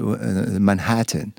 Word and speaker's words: Manhattan: Alors, c'est tout le truc des accents toniques. C'est Manhattan. – C'est ça. – Parce qu'on se demande si c'est Manhattan, Manhattan ou Manhattan. – C'est en deuxième.